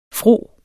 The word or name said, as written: fro